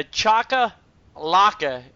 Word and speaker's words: chakalaka: It's a boerewors, a soubise, a chaka laka, and that's how it's spelled. C-H-A-K-A-L-A-K-A. Chakalaka, right? – Sounds like chakalaka to me.